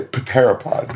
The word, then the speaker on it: pteropod: Or, as I would say, pteropod, because I can't stop doing that. – That's fine. – Yeah. – I have to pronounce the P.